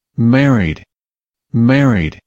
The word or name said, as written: married